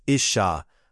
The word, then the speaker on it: Ish: Woman equals Isha, pronounced Isha, from Ish, pronounced Ish.